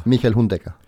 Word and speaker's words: Hundegger: Michael Hundegger.